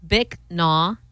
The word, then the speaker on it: Bích Nha: It's a Vietnamese name commonly pronounced as Bích Nha or Bích Nha.